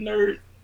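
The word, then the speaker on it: N.E.R.D: Nerd. – Yeah. We had Nerd. It's NERD, right? You got to say it like that. So. – No, you don't. You just say Nerd.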